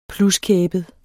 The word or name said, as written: pluskæbet